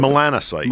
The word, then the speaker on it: melanocytes: Melancholites? How do you say it? – Melanocytes. – Melanocytes.